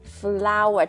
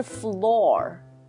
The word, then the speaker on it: flour: F-L-O-U-R, 对不对？ Right. 对 ,Flour, 它不是 floor.